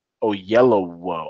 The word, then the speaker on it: Oyelowo: Oyelowo.